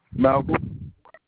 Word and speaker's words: Malcolm: Malcolm. – Spell it for me. – M-A-L-C-O-L-M. – Okay. Gotcha, Malcolm. You said M-A-L-C-O-L-M? – Yes, sir.